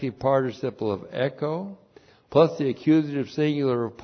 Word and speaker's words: echo: and then the verb echo, E-C-H long O, echo.